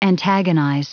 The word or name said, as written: antagonize